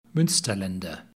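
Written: Münsterländer

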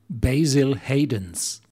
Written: Basil Hayden's

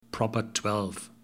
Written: proper twelve

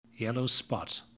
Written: yellow spot

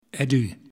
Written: Eddu

